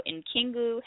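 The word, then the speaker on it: N'kingu: So Nkingu Health.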